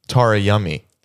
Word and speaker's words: Tara Yummy: Tara Yummy. – Yes. Thank you for saying it correctly.